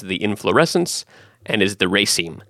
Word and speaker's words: raceme: So that's raceme.